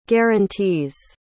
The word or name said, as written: guarantees